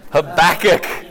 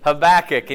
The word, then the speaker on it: Habakkuk: Habakkuk. Habakkuk.